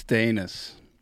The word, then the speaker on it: Danis: It's Danis. – Danis. That's what I... But okay. No. I guess I had a... – For years, I let it go mispronounced as Danis. So a lot of people got confused. I just didn't correct anybody for a long time.